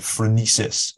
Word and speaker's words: phronesis: And you talk about in the book about Aristotle and his idea of phronesis, if I pronounced that correctly.